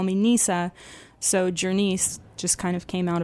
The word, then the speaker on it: Ger-Nis: So I've been mispronouncing the center as Gurney's. It's actually Jurnee's.